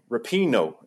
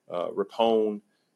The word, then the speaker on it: Rapinoe: We also interviewed Megan Rapinoe, as it's taken me a couple years to actually say her name right. Folks, stop butchering her name. I've heard Rapinoe, Rapone.